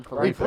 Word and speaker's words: Philippe: I feel like he wants to say Felipe if he was running for office, though. – Chris just said it's driving him nuts, so there's only one way to pronounce it now, and that's Ryan Philippe.